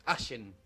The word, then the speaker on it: Allen: Igual me quedaba un paréntesis ahí para arreglar que la ciudad es Allen. – Lo dije mal.